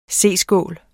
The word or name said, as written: C-skål